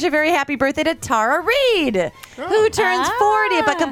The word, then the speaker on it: Tara Reid: I did not, but I would like to say that Tara Reid is pronounced Tara Reid.